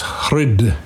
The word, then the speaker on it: hrid: The word is hrid, hrid, H-R-I-D. I have a book called, I call the Hridaya Yoga Sutra. And the hrid, heart, is two syllables, hr and da.